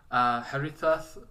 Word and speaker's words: Haritha: Harithath, how do you say it? – It's in Arabic, it's Haritha.